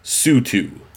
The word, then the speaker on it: Sotho: It's spelled Sotho, but he said it in the letter. It says it's pronounced Sotho.